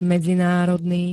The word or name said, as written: medzinárodný